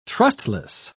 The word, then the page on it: trustless: British English